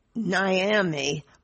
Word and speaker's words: Niami: First up, Yvonne Niami, which rhymes with Miami, although Yvonne's from California.